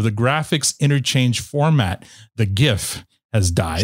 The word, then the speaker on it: GIF: According to a handful of blogs and websites, Wilhite and a graphics file formats FAQ page from 97 argue that the proper way to pronounce the format is GIF. – GIF. – Oh, thank you. – Like the peanut butter. – Yes. – Yeah. – It's fucking embarrassing! – So there you go. Soft G.